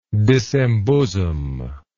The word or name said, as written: disembosom